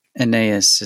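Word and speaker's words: Anaïs: Aeneas is how she pronounces it.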